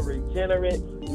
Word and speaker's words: Regenerit: Yeah, basically Regenerit on all social media, which is spelled R-E-G-E-N-E-R-I-T. The reason for the spelling is the like Regenerate and Regenerit are spelled exactly the same way. So just to let just think how it's pronounced is how it's spelled. Regenerit, R-E-G-E-N-E-R-I-T.